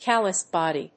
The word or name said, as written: callous body